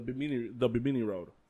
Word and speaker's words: Bimini: Did I mispronounce the Binomy? – No, the B-I-M-I-N-I. – The Bimini Road.